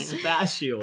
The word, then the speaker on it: spatial: It's spelled like spatula, okay? – What? Spatula. – Spatial. There should be a C in there. I'm sorry. Bug. – Spatial. – Spatial? But it's not spelled like space.